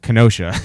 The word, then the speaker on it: Kenosha: we're like, oh no, it's pronounced Kenosha.